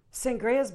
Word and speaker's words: Sangres: So it's Sangres. – Like a wine. Sangria. – Yes. Yes. – Not sangria. – Sangria? – Sangria.